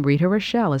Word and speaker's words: Rita Rochelle: First, my name, Rita Rochelle, is spelled R-I-T-A-R-O-C-H-E-L-L-E.